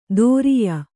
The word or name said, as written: ದೋರಿಯ